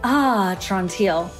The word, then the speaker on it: Atrantil: So Atrantil, yes, that's how you say it, like, Atrantil.